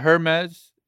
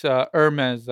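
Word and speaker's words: Hermès: It's not Hermes. It's Hermes, I believe. – Hermes, yes. It's you don't say the H.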